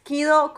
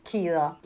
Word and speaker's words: Kiddo: Bueno, estoy en TikTok y en Instagram como arroba Kido con doble D. Los que vieron Kill Bill lo van a entender, pero no es Beatrix, es Berenice Kido.